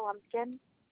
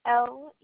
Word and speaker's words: Lumpkin: Alexandria Lumpkin. – And could you spell that last name for me? – Lumpkin, like pumpkin but with an L.